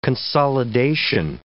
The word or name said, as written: consolidation